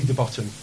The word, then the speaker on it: de Botton: And it's worth just thinking about how our CEO, Ollie de Botton, of the organisation, has often talked about education having a past, present and future. – What was his name, Ollie? – Ollie de Botton. – De Botton. How do you spell that? – D-E-B-O-T-T-O-N, okay.